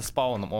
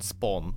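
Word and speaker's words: Spawn: Я могу ошибаться, но э, а, дифтонг A и W читается как О, длинное О, как в случае со спауном. Он спаун, он не спаун, он спаун.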